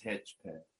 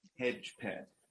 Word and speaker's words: Hedgespeth: My last name is Hedgepeth. – Say it again? – Hedgepeth. – Hedgepeth. Okay, see, and you see, Peth.